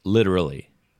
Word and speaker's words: literally: And natural pronunciation is literally. – ほら難しいもん。難しいこれね。The T is soft, sounds like a D.